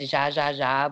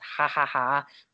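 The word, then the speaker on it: Jajaja: Okay, so I'm vegan, so I have very specific favorite restaurants, and there's one in the Lower East Side called, they pronounce it Zha Zha Zha, but it's Ha Ha Ha because we're Latino.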